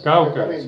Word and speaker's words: Keuchel: De Keuken, como se pronuncia. – Keuken, sí.